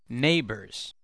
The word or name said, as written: neighbors